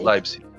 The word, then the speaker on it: Leipzig: Isso, Leipzig.